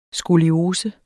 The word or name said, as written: skoliose